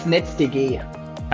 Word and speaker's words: NetzDG: How would I pronounce that in German? – Basically in German, it's NetzDG.